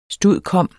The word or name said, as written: stud.comm.